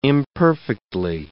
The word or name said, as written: imperfectly